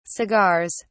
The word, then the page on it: cigars: British English